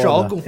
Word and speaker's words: Shogun: E sobre o nome ser um SH ou X, acho que o pior de tudo é o acento no O, Shogun. Que é foda. – Shogun? – É, Shogun. Que realmente se escreve Shogun com acento, né? E ele disse que isso vem porque no SH, no, na palavra original, o O tem aquele tracinho em cima.